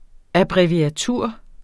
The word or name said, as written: abbreviatur